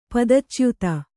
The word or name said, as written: ಪದಚ್ಯುತೆ